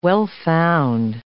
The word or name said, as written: well-found